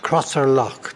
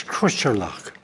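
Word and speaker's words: Crosserlough: For instance, there's a club in Cavan, and Crosser Lough. That's the way you say it. But it's not. It's Crusher Lough. That's the way they go.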